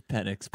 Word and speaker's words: Penix: Did I just pronounce it? I turned it into a dirty word? Penix? – It's Penix.